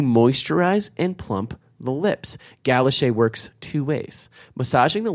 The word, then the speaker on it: Galocher: Now that is spelled G-A-L-O-C-H-E-R. – French. – But she put it phonetically for me. Galoche.